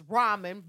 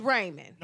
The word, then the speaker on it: ramen: Look, okay, so we used to have this debate in high school because, yes, technically I know it's ramen, but growing up, it was Raymond.